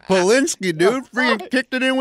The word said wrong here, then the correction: Pulisic: Please pronounce Pulisic.